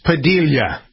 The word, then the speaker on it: Padilla: Okay, so Alex Padilla, the new council.